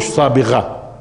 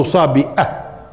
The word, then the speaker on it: صابئة: لأنه أصلا هم في لغتهم المندائية، في اللغة المندائية، الغين اللي عندنا في العربي غين، هم بيلفظوها همزة، هم بيقولوش صابغة، هم بيقولوا صابئة، بيقولوش صبغة، بيقولوا صبأة، صبأة.